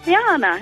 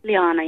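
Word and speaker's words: Lehanna: Liana, hello. – Liana. – Hello. – How are you pronouncing it? Liana. – Liana, yeah. – Liana.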